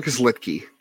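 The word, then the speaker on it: Luetge: Lucas Litke. It's a V. Yeah, that is not one of your things you have to worry about. That is it is not spelled like it sounds.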